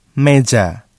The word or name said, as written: meja